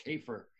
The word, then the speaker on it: Kaefer: Just Google Carl Kafer. And yes, you pronounce it right at the beginning. I'm so pleased about that. And that, of course, is K-A-E-F-E-R and Carl with a K.